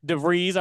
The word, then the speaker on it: DeVries: But DeVries, I did find out that it is pronounced DeVries. I heard Seth Davis talking about the pronunciation.